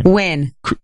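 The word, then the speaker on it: Nguyen: How would you pronounce the last name? – Win.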